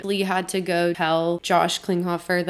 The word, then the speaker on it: Klinghoffer: Anyways, so then after that, John Frusciante was replaced by Josh Klinghoffer.